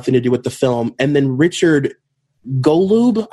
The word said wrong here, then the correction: Golub: I know I'm pronouncing his name wrong. It's Gulab. Um, he was this author.